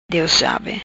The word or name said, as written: Dios sabe